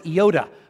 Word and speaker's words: iota: In case you were wondering, an iota, it's almost pronounced like Yoda from Star Wars. Okay, the actual word is iota. Okay, that's how you say it in the original.